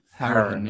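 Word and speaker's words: Hourihane: Howrahan. Yeah, but that's actually often got wrong in Ireland. That's his Cork. That's a Cork pronunciation, but he's from Cork.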